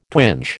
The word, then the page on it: twinge: American English